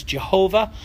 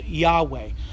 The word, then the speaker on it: Yahweh: So in olden times, people used to pronounce that, you know, as Jehovah. That's where that comes from. But really, more properly, it's called Yahweh.